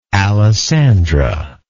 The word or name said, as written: alessandra